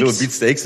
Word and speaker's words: Beatsteaks: Das ist auch richtig. Ja. Hast du richtig ausgesprochen. Beatsteaks kam ja, genau. Ja, Beatsteaks oder Beatsteaks? – Nee, Beatsteaks ist richtig. Ich weiß nicht, kann ja jeder machen, wer will, aber Beatsteaks, glaube ich, ja.